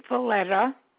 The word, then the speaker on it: Valletta: Mary Valletta. V-A-L-L-E-T-T-A. – I need you to... Okay, is Mary spelled M-A-R-Y? – Yes.